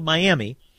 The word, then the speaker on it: Miami: There's a little town of Miami, Oklahoma, which is spelled Miami, but pronounced Miami, which is a great place to stop.